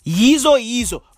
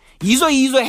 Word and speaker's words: Yizo Yizo: You want me to spell it? Y-I-Z-O. Y-I-Z-O. Okay? Yeezo Yeezo. Bro. Yeezo Yeezo had season one.